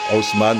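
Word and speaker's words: Houseman: Je dis mal, je la prononce à l'anglaise. Ça, s'écrit house comme maison, man comme un homme, Haussmann, l'homme de la maison.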